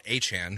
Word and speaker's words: Achane: And I think, and with that, because it just adds to the Dolphins' talent, you know, I'm very curious if Devon Achane, because that's apparently how you say it, is also this for real.